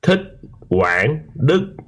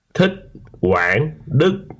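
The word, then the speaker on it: Thích Quảng Đức: Thich Quang Duc. – What? – Thich Quang Duc. – Thich Quang Duc? – That's how a male Vietnamese person says it.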